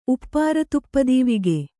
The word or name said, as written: ಉಪ್ಪಾರತುಪ್ಪದೀವಿಗೆ